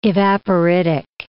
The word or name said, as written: evaporitic